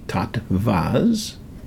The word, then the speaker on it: tattvas: But you could go on the internet and put in TATTWAS, pronounced with a V, tattvas.